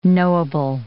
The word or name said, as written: knowable